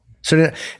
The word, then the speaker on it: Suriname: Suriname.